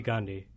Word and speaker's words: Gandhi: Yeah, Ricky Gandhi. – Ricky Gandhi. And spell that last name for our listeners. – Yeah, G-A-N-D-H-I.